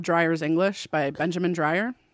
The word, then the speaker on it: Dreyer: No, D-R-Y-E-R. D-R-E-Y-E-R. I'm so sorry. – D-R-E-Y-E-R. – Yeah. – Oh, I thought it was Dreyer's English. Like no. – Somebody's in the dryer. – No. – And they, and, let me out of here! Exclamation point! that's Dreyer's English.